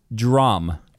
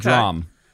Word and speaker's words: DRAM: His name is pronounced Drum. – Drum. Okay. – Drum. – All right. – And don't feel bad because when Sway first interviewed him, he called him Dram. And he said, "No, I'm not Dram. I'm Drum. – I'm Drum."